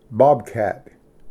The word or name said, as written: ᎬᎮ